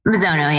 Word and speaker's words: Mizono: Mizono, yeah.